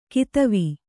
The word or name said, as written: ಕದವೆ